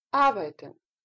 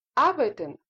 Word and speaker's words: arbeiten: Это слово произносится с ударением на первый слог. Большая просьба запомнить, потому что звучит не очень красиво и режет слух, если вы говорите arbeiten. Не arbeiten, а arbeiten. Так будут говорить немцы.